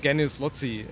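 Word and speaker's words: genius loci: We pronounce it genius Lotzi. It's in Latin.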